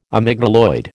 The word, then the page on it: amygdaloid: American English